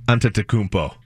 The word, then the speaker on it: Antetokounmpo: I like Giannis Antetokounmpo. Is that how you say it? – Antetokounmpo.